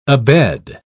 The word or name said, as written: abed